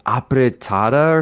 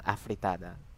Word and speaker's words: afritada: Well, I grew up, my mom would make, you know, pancit, and, is it pronounced apretada or something? – Oh my god, yeah, F sound, afritada. – Apretada, oh my god, it's with an F, afritada?